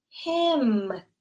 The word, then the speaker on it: hymn: And it is spelled H Y M N. And the N is totally silent. Hymn. It sounds exactly like the other word, the pronoun him, they are pronounced exactly the same.